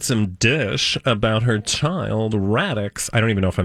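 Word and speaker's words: Raddix: Radix. Rhymes with Maddox.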